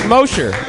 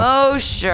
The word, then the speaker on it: Mosher: It looks like Nathan Mogev. Put your hands together, yay! – Mosher. – Oh, okay, Mosher. – That's how you say it. Yeah. – Mosher.